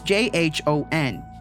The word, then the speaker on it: Jhon: So it's J H O N.